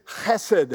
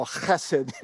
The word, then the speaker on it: chesed: That is his chesed. That's how you say it in the Hebrew. You got that guttural chesed. It's like you've got a cold or something. You got to get it out.